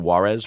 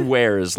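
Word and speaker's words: warez: And then you would go to some sort of like Wares, spelled W-A-R-E-Z. I think I pronounced it Juarez for a long time. – Oh, Juarez. – Yeah, but I think it's Wares. Like, oh, check out my Wares.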